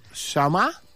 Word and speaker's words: Chama: ¿Cómo se pronuncia bien tu apellido? ¿Chama? – Chama. – Como está escrito.